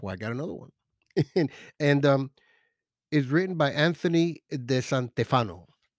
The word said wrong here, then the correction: De Stefano: Anthony De Stefano. De Stefano. Okay, I said it wrong.